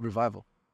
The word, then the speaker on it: revival: Nessa quarta hipótese, você vai manter essa experiência antiga, porque é o que você falou, é re, revivar, né? Revivar não. Como é que fala? Revival. É.